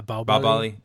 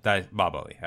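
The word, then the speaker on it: Baahubali: How do you say it? Bahubali? – Is that Bahubali? Yeah, Bahubali. – Bahubali. All right.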